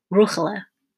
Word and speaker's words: Rahela: He pronounced her name like it would have been pronounced in her hometown, Ruchela.